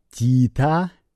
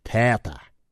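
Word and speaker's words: theta: Они перестали говорить «Тита». Вы знаете, вы слышали такие выражения, как «Тита Клир» и так далее. И теперь они произносят это как «Тета».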